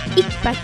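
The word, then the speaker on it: icpac: La preposición encima en Náhuatl se dice ikpak.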